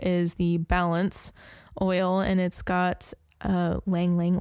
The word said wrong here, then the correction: Ylang Ylang: oil. I can never say that. – Ylang yep. Ylang. – It's Ylang Ylang. – Ylang Ylang. I can never say that right.